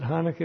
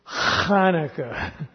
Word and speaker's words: Hanukkah: It is not Hanukkah at all. It should never be called Hanukkah. It's Hanukkah. When you say it, the person in front of you should be completely disgusted at you for saying it properly. But that's it. It's Hanukkah. It's a guttural.